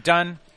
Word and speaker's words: Dunne: It's spelled so weird. – D-U-N-N-E. – I know. But I'm saying, but it should be Dune if there was one N. – I know, but the E is what messes me up.